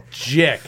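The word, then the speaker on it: Gic: Jick. So, it sounds like dick.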